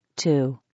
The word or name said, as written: 2